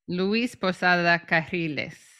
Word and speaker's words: Luis Posada Carriles: Luis Posada Carriles. I'm not actually good at rolling my double R.